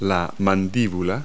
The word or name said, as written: jaw